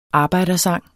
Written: arbejdersang

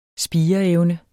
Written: spireevne

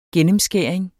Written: gennemskæring

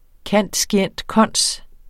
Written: cand.scient.cons.

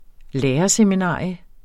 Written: lærerseminarium

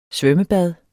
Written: svømmebad

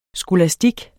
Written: skolastik

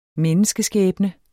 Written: menneskeskæbne

